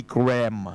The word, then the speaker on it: Graham: 葛培利咧，但係即係中文譯名啦，但係原來係 Billy Graham 啊。嗰個 H 字係完全唔發音嘅音工，係嘛？